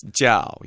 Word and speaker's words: Zhao: It's Zhao, yeah. It's like it's a version of the traditional Western J sound. It has a little bit of a D in it, like a DJ sound, a little bit of a Y, Zhao.